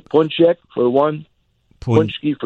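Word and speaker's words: pączek: One is P A C Z E K. – Okay. Pronounce Punchek. – It. Punchek. – And there's a little half a square under the A to make it an OM instead of an I.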